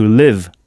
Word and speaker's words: live: Il faut bien prolonger le son to leave à ne pas confondre avec to live, avec un I court qui s'écrit LIVE, qui veut dire vivre, habiter.